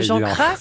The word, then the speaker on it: Cras: Oui, mais j'arrive pas à bien faire la fin de Jean Cras, mais il faudrait le faire entre le S et le Z, d'après ce qu'on a compris.